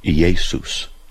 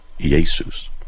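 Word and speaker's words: Iēsous: Now, the Greek word is actually pronounced Iesus, Iesus. And so, but, you know, we say Jesus, and that's fine.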